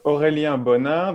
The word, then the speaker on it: Aurélien Bonin: So my name is uh, Aurélien Bonin. That's the French pronunciation.